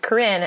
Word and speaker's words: Corinne: Because I was just thinking that maybe my mean voice should be called Karen, because Corinne is so often pronounced Ooh. As Karen, like at Starbucks. And I'm like, oh, my name's Corinne. And they spell it like K-R-I-N.